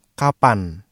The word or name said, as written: kapan